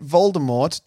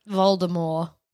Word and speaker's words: Voldemort: J.K. Rowling initially meant Voldemort to have a silent T, like the French. – Voldemort.